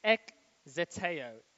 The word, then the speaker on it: ekzeteo: So the word diligently seek in the Greek is ekzeteo. If you're writing notes, it's spelled E K Z E T E O.